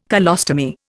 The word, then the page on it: colostomy: Indian